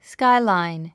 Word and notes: skyline: skáilàin; スカイラァイン

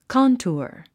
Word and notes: contour: kάntuər; カントゥァ

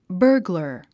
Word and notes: burglar: bə’ː r glə r; バーグラァ

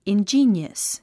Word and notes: ingenious: indʒíːniəs; インジィニアス

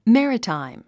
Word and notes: maritime: mǽrətàim; メラタイム

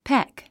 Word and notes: peck: pék; ペェック

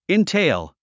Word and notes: entail: intéil; インテェイオゥ

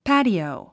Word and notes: patio: pǽtiòu; パァティオ

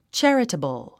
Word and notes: charitable: tʃǽrətəbl; チャラタブル

